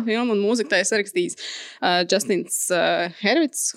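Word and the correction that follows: Hurwitz: Hurvits. Uh, nezinu, kā mēs viņu latviešu tā smuki izrunāsim, bet uh, viņš arī sarakstīja uh, citām Čezela filmām mūziku, tostarp arī Lālēnu, par ko viņš dabūja Oskaru.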